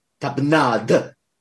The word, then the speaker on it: tapenade: How to pronounce it correctly? And the in the French the D is pronounced. The D is real hard. Tapenade. – Real hard. – So it's a real hard D. – Yeah, it's a real hard D. So it's tapenade. – How erotic. – And so that's why you have that duh sound in the pronunciation tapenade.